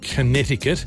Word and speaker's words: Connecticut: US state of Connecticut actually has got a C in it. So if you're doing it phonetically, it's Connecticut.